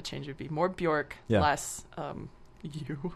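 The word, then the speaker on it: Björk: It's Bjork. Rhymes with jerk.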